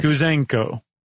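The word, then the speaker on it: Gouzenko: Apparently right after World War II, there was a cipher clerk, a cryptologist named Igor Guzenko. – Nice. – Guzenko. I added an extra syllable, I think.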